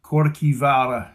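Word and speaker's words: Kirkjuvágr: It's actually pronounced Corkivara in Old Norse.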